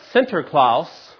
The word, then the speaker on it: Sinterklaas: In German, it would be pronounced Sinterklaas, which is how we kind of get our Americanized version of Santa Claus.